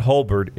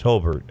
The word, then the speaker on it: Hobart: Like, it's like not Hobart. It's, you know, some people want to say Hobart. It's not Hobart. Yeah. It's Hobart.